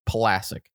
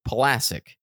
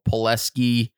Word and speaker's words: Pulasic: Like for me, my last name is Pulasic. You guys know that now because classic rhymes with Pulasic. But I've been called Pulasic. I've been called Pulaski, Pulaski.